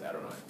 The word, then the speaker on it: Adonai: So Jews at that, or still, I think, say Adonai, where it says Yod-Heh Vav-Heh in the text of Hebrew, it's pronounced Adonai, to just mean Lord.